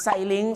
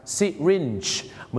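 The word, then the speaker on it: syringe: ไซลิงไซลิงหรือซาลิงซาลิงไซลิงนี่นะครับเขาไม่ได้ออกเสียงว่าไซลิงนะครับไม่งั้นเดี๋ยวฝรั่งเขาจะนึกว่าคุณจะเอาเขาขึ้นสลิงห้อยโหนในโรงสิครับที่จริงแล้วนะครับคำว่าเข็มฉีดยาเขาออกเสียงว่าซิรินจ์นั่นเองครับซิรินจ์เ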